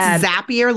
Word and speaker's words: Zapier: It's Zapier, like you zap someone.